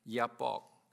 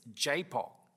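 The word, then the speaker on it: Jabbok: I grew up pronouncing it Yapok, but apparently it's Japok, or whatever way you pronounce it.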